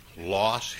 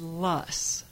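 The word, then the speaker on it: Loess: Is it Lus Hills, Loss Hills, Less Hills? – Well, it's Lus, and it rhymes with fuss. And it stems from a German word, and so we've heard it pronounced many different ways.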